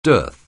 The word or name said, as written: dearth